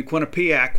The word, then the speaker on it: Quinnipiac: And then Quinnipiac will be here at Mississippi State. That's correct. I did say it correctly.